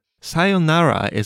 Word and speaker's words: さようなら: You have to make sure to hold the yo sound. さようなら。 – And the whole tone of the word stays flat.さようなら。